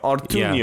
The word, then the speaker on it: Ortuño: How do you pronounce the N with the squiggle? – Ño. – Ortuño, yeah.